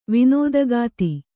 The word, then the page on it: ವಿನೋದಗಾತಿ: Kannada